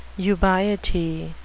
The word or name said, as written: ubiety